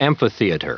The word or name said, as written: amphitheater